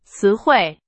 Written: 词汇

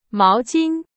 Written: 毛巾

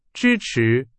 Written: 支持

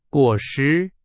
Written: 果实

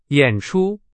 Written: 演出

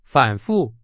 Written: 反复

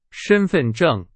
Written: 身份证

